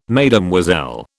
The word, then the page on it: Mesdemoiselles: British English